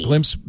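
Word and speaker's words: Glympse: And that's G-L-Y-M-P-S-E. – Glimpse spelled with a Y.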